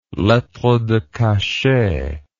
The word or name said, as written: lettre de cachet